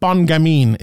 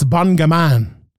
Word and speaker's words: Bon Gamin: Also, another takeaway I took from this was, for the first time ever, and I never even realized it, Cyril Gane's nickname is not Bon Gamin, it's Bon Gaman, which Cyril Bon Gaman Gane, it rhymes.